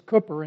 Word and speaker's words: Cowper: English, American pronunciation, Cowper. It's Cooper in Britain. So when you go to Britain, don't say it wrong among Christians there.